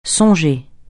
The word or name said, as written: songer